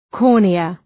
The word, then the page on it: cornea: ‘kɔ:rnıə